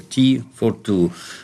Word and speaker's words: T-42: Т, буква Т, 4 и 2. Т-42, а читается оно как T-42, как чай для двоих.